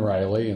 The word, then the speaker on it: Reilly: Sure, it's Kevin Riley, and the last name is spelled R-E-I-L-L-Y.